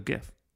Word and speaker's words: GIF: So GIF. So, yeah. But it says right here, declared it should be pronounced GIF, like the brand of peanut butter, rather than a hard G sound.